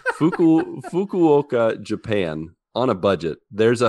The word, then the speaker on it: Fukuoka: I have to be really careful how I pronounce this. But if you ever want to visit Fukuoka, Japan, it's Fukuoka. I had to really think about this.